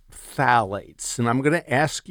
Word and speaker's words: phthalates: It's P-H-T-H-A-L-A-T-E-S. And when you pronounce it, ignore the first P-H. So just pretend like it's just T-H-A, so phthalates.